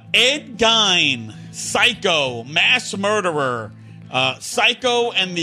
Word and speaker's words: Gein: It's pronounced Gein, by the way.